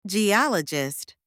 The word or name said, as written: geologist